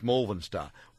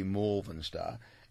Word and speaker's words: Malvern: Rick says, on suburb pronunciation, Malvern Star is pronounced Malvern Star. Well, it depends on where you come from, mate. If you're in South Australia, it'd be Malvern Star. If you come from the western suburbs of Sydney like I did, it's Malvern Star. It's spelled M-A-L-V-E-R-N, Malvern, as in Malvern Star.